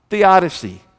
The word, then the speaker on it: theodicy: It's spelled T-H-E-O, Theo, that's God, D-I-C-Y, theodicy.